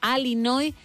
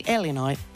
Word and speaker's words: Illinois: Una cosa es Illinois y otra cosa es Illinois. – Illinois. – E, Illinois. O Illinois, no I, Illinois.